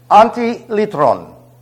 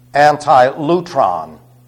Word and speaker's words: antilytron: The first one is antilitron. I'll spell it for you. A-N-T-I-L-Y-T-R-O-N. Now, for you Bible school students that learn this in some seminary or Bible school, you were taught it was anti-lutron. That's an epsilon, not an upsilon.